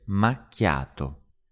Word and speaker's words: macchiato: And the pronunciation of macchiato can be a bit tricky at first. It's mac-ya-to. Mac-ya-to. Macchiato.